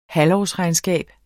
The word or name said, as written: halvårsregnskab